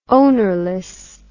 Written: ownerless

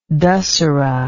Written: dasehra

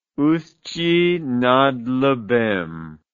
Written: Usti nad Labem

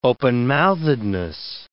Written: openmouthedness

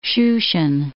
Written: Shushan

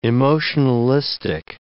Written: emotionalistic

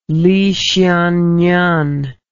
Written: Li Xiannian

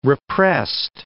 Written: repressed

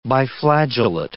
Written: biflagellate

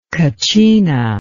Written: katcina

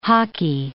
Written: hockey

